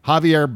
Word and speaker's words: Javier: Well, and the pronunciation of his first name is? – Javier Becerra.